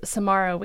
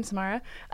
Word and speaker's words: Samara: Samara Weaving, right, is how you say her name, Samara.